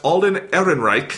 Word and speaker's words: Ehrenreich: But they announced Alden Ehrenreich, I think I'm saying that right.